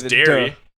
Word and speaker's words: Derry: It's Derry. You're just pronouncing it right. You're just spelling it wrong.